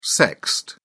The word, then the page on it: sexed: British English